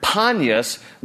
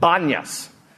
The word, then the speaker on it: Banyas: Now, when it came into Arab control before Israel recaptured it after the 67 war, it's kept the Arabic pronunciation of the Greek word Panyas, but the Arabs can't pronounce a P, so they named it Banyas. So if you ever really want to make an Arab guy go crazy, ask him to repeat Peter Piper picked a peck of pickled peppers. It'll drive him crazy. But anyway, so they can't pronounce peas very well, so it's Banyas.